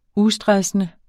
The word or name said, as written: ustressende